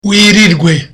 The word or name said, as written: wirirwe